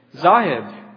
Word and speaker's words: Zahid: When I came to the United States, I realized that my last name would be mispronounced. Zahid, Z-A-H-I-D. It's very easy. But Zahud, Zahad, Zahid seem to be the top choices for people when they call me.